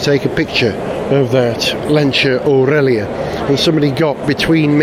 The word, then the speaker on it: Lancia: Four magnificent Lancias. And it is pronounced Lancia. Don't let anyone tell you it's Lancia. I know that because Paolo Pininfarina himself said Lancia.